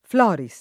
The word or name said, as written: Floris